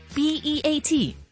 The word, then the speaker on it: beat: 成日听到人哋讲啊，我哋跟住个 beat 韵啦，连 Michael Jackson 啊都有一只歌叫做 B E A T，再加一个 it，咁到底 B E A T 呢个字应该点样发音呢？转头话你知。该读做 beat，它是属于英文的长母音，既然有长自然就有短，跟它类似发音的字呢就有这个了， bit， bit， bit，跟刚才讲的 beat 对比， bit， bit， bit，有短促的感觉，而 beat 呢就读做 beat beat，系有刻意拖长嘅感觉嘅。